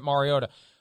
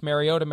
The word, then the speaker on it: Mariota: And thank you for pronouncing it Mariota. I don't want to name names, but my God, when he was playing last Thursday night, it's Mariota, Mariota, Mariota.